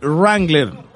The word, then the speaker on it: Wrangler: La W no se pronuncia Wrangler.